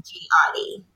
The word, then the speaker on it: Gehati: So Gehati, okay, it's spelled G-E-H-A-T-I. A lot of people pronounce it Gehati, but the H is silent. So it's okay. Just Gehati. And yes, Gehati.